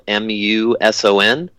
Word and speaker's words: Muson: And again, my name is Mike Muson. It's spelled Muson.